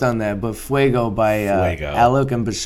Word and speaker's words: Alok: So, I fell in love with this song by I believe you say it like Alok and Bashar. A-L-O-K and B-A-S-H-A-A-R.